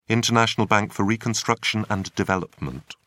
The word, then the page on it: international bank for reconstruction and development: British English